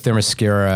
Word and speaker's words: Themyscira: Themyscira, I think is that how you pronounce it? – Yes. – Good job.